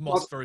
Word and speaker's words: Moskva: I mean, you can either call it Dynamo Moskva or Dynamo Moscow because it's the same place. It's just Moskva is the way that the Russians pronounce it.